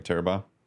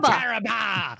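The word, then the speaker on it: Tarrabah: Oh, I'm glad you said it right, Tefty, because if you're going to mention an Australian exotic, you got to say it like an Aussie, Taraba! Taraba!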